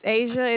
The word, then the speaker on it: Asia: My name is Asia. It is spelled A-S-I-A.